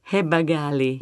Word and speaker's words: Heba Ghali: Heba Ghali.